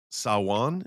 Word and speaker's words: Samhain: I mean, there are so many holidays that even going back to like Samhain is how you pronounce it. – Yeah, Samhain. Yep.